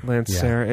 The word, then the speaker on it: Lancyre: Lancerre. Yeah, it's spelled, Domaine is obviously Domaine, and then D, and then L-A-N-C-Y-R-E. – L-A-N.